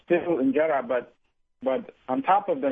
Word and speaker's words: injera: And I love the teff bread injera, how is it pronounced? – Injera.